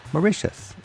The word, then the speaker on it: Mauritius: Let's go to Mauritius. Mauritius, it rhymes with delicious.